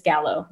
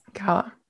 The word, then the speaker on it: Gallo: So, digital creator, NYC, influencer marketing, life at Gallo. Is that how they pronounce it? – Yeah. So that's just the Instagram, but it's really my employer, which we'll speak about in a little bit, is Gallo. Yes.